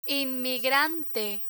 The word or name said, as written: inmigrante